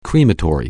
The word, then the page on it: crematory: anglais